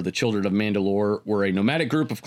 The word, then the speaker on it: Mandalore: The leader of the Mandalorians was known as the Mandalore, translating to soul ruler, and was rendered as, it's all spelling, doesn't matter, it's all pronounced Mandalore.